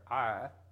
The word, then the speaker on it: Ai: He pronounces every one of these names different than I did. I've always said it, Ai, but he pronounces it I.